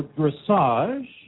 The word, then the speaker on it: Dressage: We have a great response from the wonderful and outstanding fans of Dressage, or Dressage USA, at the national championships here at Gladstone, New Jersey. – Oh, that's embarrassing. I've been pronouncing it dressage. Evidently, it's dressage.